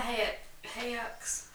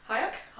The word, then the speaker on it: Hayek: It's kind of Salma Hayek's... – Hayek? – Hayek.